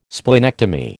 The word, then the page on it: splenectomy: American English